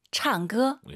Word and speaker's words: 唱歌: 창, 너무 꺼 하지 마시고 우리 선생님처럼 힘을 빼시고 唱歌. – 예. 쌍기역보다는 좀 기역과 쌍기역의 느낌인 것 같아요. 가운데 느낌, 창조.